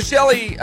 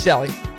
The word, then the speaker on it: Chelle: So I had to ask her, I said, is your name pronounced Shell like Michelle or Shelly? – Yeah. – Like Shelly. – Yeah. – It's Shelly.